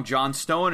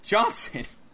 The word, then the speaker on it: Johnson: He's like, they spelled your name wrong, John Stone. And he's like, no, it's Johnson.